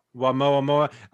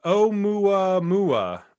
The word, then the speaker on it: Oumuamua: Oumuamua. I just read on the NASA site that it's pronounced Oumuamua. Oumuamua.